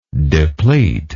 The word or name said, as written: depletory